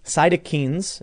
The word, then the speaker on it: cytokines: Ibuprofen increases cytokines. Is that how you say it? – Yeah, it's called a cytokine storm.